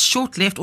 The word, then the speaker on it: ShotLeft: So is it, it's short left, or short, we spell it S-H-O-T left, short left. – That's correct. – Okay. – S-H-O-T and left.